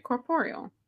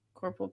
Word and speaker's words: corporeal: Yeah, you're saying it right. Corporeal. Corporal property. I almost said corporal property. That's not it.